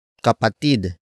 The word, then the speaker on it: kapatid: And that noun is kapatid. Kapatid. Kapatid. Kapatid is spelled as K-A-P-A-T-I-D.